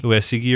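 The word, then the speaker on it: Uesugi: But, Usagi. – Yeah, that too. Usagi. – Is that what it is? – It's, in Japanese, you pronounce every vowel. And so it would be Uesegi or whatever.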